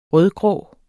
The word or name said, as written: rødgrå